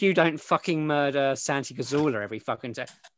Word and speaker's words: Cazorla: It's Cazorla, that's how you say it.